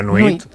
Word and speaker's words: nuit: La nuit. – La nuit. – Nuit, muy bien. O sea, wit, nuit. Perfecto.